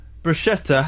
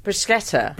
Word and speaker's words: bruschetta: In Italian, bruschetta has a hard C and is pronounced with a sk, not a sh sound. If it didn't have a h, it would be soft. – Bruschetta.